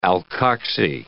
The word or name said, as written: alkoxy